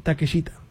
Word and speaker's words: Takeshita: ¿Takeshita o Takeshita? – Es... Takeshita. – Es que he visto que muchos le pronuncian como Takeshita.